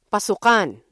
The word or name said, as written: pasukan